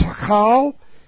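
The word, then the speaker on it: hekal (היכל): The Hebrew word for temple is hekal. C-H-E-K-A-L is the way it's pronounced, signifying a palace.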